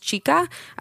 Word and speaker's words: Chika: Her name is, I believe it's pronounced Chika, C-H-I-K-A.